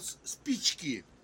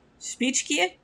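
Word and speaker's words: spichki (спички): In Russian, matches called spichki. – Spichki? – Yes, spichki. – Spichki. Did I pronounce that right? – Yes, right. Spichki. – Oh, good. – Like speechki.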